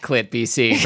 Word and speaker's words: KLITBC: Yeah, and you're listening to KLITBC, that's pronounced ClitBC Radio, making the people of British Columbia very, very happy, uncomfortable or uncomfortable.